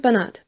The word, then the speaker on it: Barnard: Und du bist?« »Florence Barnard. Betonung auf der zweiten Silbe, wenn's recht ist.